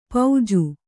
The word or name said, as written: ಪೌಜು